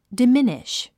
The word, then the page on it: diminish: dəmíniʃ; ダミニシュ